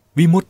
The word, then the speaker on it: วิมุตติ: วิมุตติที่เป็นภาวะและอาการสะกดดังนี้คือวอแหวนสระอิมอม้าสระอุตอเต่าและตอเต่าสระอิซึ่งอ่านว่าวิมุตติหรือถ้ามีคำมาต่อท้ายก็จะออกเสียงเป็นวิมุตติเช่นวิมุตติญาณทัสสนะวิมุตติสุขเป็นต้น